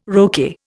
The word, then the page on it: roquet: Indian